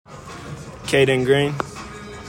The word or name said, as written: KAYDEN GREENE